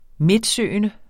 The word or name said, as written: midtsøgende